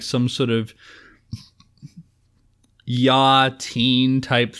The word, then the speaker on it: YA: It's Yah. YA. – I just say YA. – Young adult. It's pronounced Yah. I'm telling you.